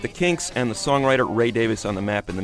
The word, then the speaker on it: Davies: Ray Davies, that's the way he prefers to have his name pronounced.